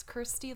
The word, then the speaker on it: Kirsty: Kirsty, hello. – Can you walk us through that pronunciation carefully? Because that was difficult for me meeting you. – Totally. It's Kirsty like thirsty.